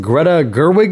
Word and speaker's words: Greta Gerwig: We got some Greta Gerwig news, Andy? – Yes. – Is that how you say it? – Yes, you got it.